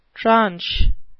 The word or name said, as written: tranche